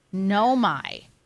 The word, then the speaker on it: Naomi: Naomi.